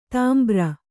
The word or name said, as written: ತುಂಬುರ